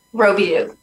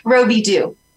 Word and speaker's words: Robidoux: Robidoux. – Robo what? – Robidoux.